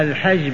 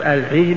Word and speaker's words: الحج: الحج بفتح الحاء والحج بكسرها، أحسن ما تقول هما قراءتان، قراءة نافع الحج بالفتح، وقراءة حفص الحج بالكسر. والأصل فيهما لغتان، لغة أهل نجد الحج، وأهل الحجاز الحج، لأن الأنسن تختلف بحسب المناطق، بلد واحد ويختلف الناس في النطق. إذن اقرأ الحج والحج ولا تتحرج، الكل جائز وواسع.